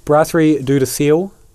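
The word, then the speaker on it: Brasserie Dieu du Ciel: Brasserie Duderseel. Is that how you say it? – Yeah, that sounds right.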